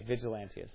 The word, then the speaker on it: Vigilantius: You said Valentius? – I said Valentinius. – Why'd you get it wrong? I thought you were pronouncing it right and I was pronouncing it wrong, maybe. I was thinking you pronounced it eloquently like Apollos, and I pronounced it rude in speech like Paul. Right? That's what I thought. Okay, Vigilantius. I was right then, okay.